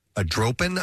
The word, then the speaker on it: adropin: Only men experience decline in a protein, which is known as adropin, I believe is how you say it. – Andropin? – No, it's A-D-R-O-P-I-N. There's no N.